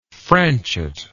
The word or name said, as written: franchot